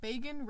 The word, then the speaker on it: Begin: Begin, Reagan, Palestine. Begin, but it's spelled like begin, but he says it in the song like Begin. But maybe he just says it so it rhymes with Reagan.